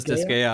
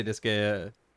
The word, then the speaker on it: Disgaea: Antoine says Disgaea. I say Disgaea. I've heard people say Disgaea. – I will copy you guys, Disgaea.